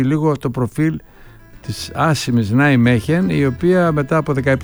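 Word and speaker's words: Nijmegen: Αυτός ήταν που μας μετέφερε το τι είδε στην Ολλανδία, στον Νάιμέχεν, όπως προφέρεται.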